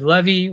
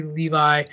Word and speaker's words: Levi: I always did Levy, which was not correct. But Zachary Levi,